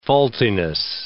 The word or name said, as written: faultiness